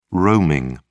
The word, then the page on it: roaming: British English